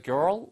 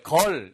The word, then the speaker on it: girl: 이거 girl 이게 아니고요. Girl 한 다음에 뒤에 튀기면서 girl. Girl. 혀가 이렇게 나왔다가 이렇게 들어오시면서 girl. Girl처럼 발음하시면 돼요.